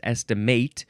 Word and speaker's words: estimate: It's estimate, and yes, this one is pronounced estimate with a long A because it's the first form, which is a verb, and I think it's just transitive from circa 1532.